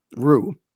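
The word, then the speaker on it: ruh (روح): That's ruh with an h sound at the end, which probably didn't come across. It's that kind of thing we don't tend to pronounce in English, but it's super important in Arabic and Hebrew for that matter.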